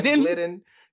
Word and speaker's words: Glidden: Glidden.